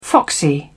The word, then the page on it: foxy: British English